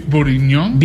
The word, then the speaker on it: bourguignon: Ver, ¿cómo se pronuncia? – Beef bourguignon. – Beef bourguignon.